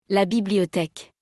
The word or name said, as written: la Bibliotheque